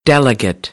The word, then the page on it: delegate: American English; ə - ɪ